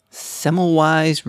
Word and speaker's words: Semmelweis: Semmelweis reflex.